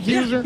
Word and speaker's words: Hughes: Did I say it right, Hughes? – Yeah, sounds good to me.